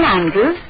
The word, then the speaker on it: Andrews: Helen Andrews, 33 Bedford Road. – Helen Andrews. Would you please spell that? – A-N-D-R-E-W-S.